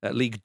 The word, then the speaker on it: Ligue: Amiens are currently 10th in League 2. Ligue 2, should I say, as we all say Ligue 1, so keep it French.